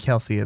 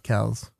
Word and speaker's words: Kelce: It's not Kelce, it's Kelce.